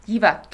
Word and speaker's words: Jiva: I don't know how to pronounce it, but like Jiva, which is, yep. Jiva.